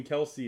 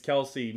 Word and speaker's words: Kelce: and He said, "Yo, bro, my name isn't even Kelsey. It's Kels. Y'all all say it wrong." And literally, his mom had to go. Like they got his mom on ESPN to go out there and be like, "It's Kelsey. Move on.